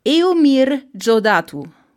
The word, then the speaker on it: Eumir Deodato: Das, der ist von, ich habe immer falsch ausgesprochen, ich habe heute mal nachgeschaut, wie man den ausspricht. – Oh, sehr gut. – Eumir Jodatu. Also ah. Eumir Deodato. Bis, mhm. bis heute Morgen gedacht. Das ist also, sehr ausgesprochen. Eumir. – E Eumir Deodato. Also Eumir Jodatu.